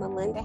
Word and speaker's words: Melinda: So my name, Melinda Harris, on Facebook, and that's spelled M-E-L-I-N-D-A, last name Harris, H-A-R-R-I-S.